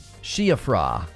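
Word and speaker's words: Siofra: See, Ofra Woods is pronounced Sheafra. It's Irish for fairy?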